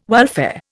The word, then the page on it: welfare: Indian